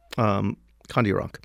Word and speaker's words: Kandiaronk: K-A-N-D-I-A-R-O-N-K. It's usually spelled with an O, but like as people now, Iroquois speakers say, can't really be an O.